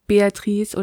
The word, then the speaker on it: Beatrice: Beatrice hat ein Buch geschrieben. Für wen ist das denn gedacht und worum geht's? – Also erstmal vorweg geschickt, also Beatrice oder Beatrice, wie sie eigentlich italienisch richtig ausgesprochen wird, ist jetzt keine, die jetzt mal eben so eine Adlige, die mal so ein Buch schreibt.